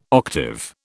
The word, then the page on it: octave: British English